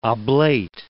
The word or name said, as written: oblate